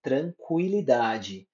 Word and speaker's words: tranquilidade: Exemplo, 50 tranquilidade. Nesse caso, é como se eu estivesse falando CU em vez de QU. Por isso que é 50, tranquilidade.